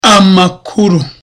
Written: amakuru

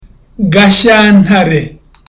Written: Gashyantare

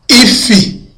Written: ifi

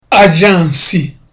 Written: ajansi

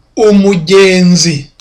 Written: umugenzi

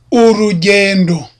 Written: urugendo

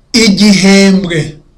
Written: igihembwe

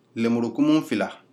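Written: ߟߋ߬ߡߎ߬ߙߎ߬ߞߎ߬ߡߎ߲߬ ߝߌ߬ߟߊ߬

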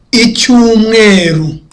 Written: mucyumweru

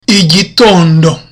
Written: igitondo

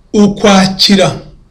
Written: ukwakira

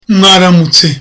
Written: mwaramutse

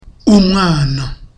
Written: umwana

